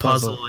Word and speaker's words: Fuzzle: It's pronounced puzzle in Korean anyway. Okay. – Puzzle. – They don't have an F sound.